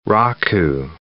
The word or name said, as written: raku